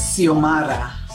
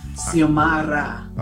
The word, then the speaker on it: Xiomara: It's Xiomara. – Xiomara. It's got a, like a... – Right, the X is silent, and it's Ra, Xiomara.